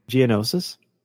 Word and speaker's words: Geonosis: Geonosis.